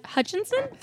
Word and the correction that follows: Hutcherson: Three. – Hutcherson? – Two. – Hutcherson. – Hutcherson. – Josh Hutcherson.